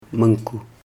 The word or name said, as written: munku